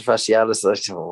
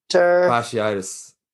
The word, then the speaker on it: fasciitis: Plantar fasciitis, whatever way you pronounce it, I probably butchered that. – You did. – but I think it's, what is it, plantar fasciitis. Actually, I put a bunch of there saying it myself, so we'll go fasciitis.